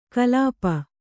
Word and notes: ಕಲ್ಪಿ: Kannada

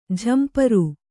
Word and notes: ಝಂಪರು: jhamparu

